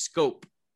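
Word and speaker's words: Schoop: Oh, and it's pronounced scope. – scope. obviously thank you, OGG host.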